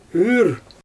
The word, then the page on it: yr: Xyzyl